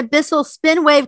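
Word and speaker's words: Bissell SpinWave: Like people could win a Bissell spin wave cordless hard floor spin mop.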